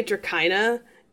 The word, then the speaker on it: Dracina: You got it mostly right. It's Runaway Dracina. It's like a Greek word or something that just means dragon, but fancy.